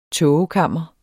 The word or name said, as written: tågekammer